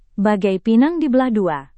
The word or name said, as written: bagai pinang dibelah dua